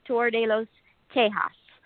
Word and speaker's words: Tour de los Tejas: And yes, your pronunciation was a little more French rather than Tex-Mex. It's actually Tour de los Tejas.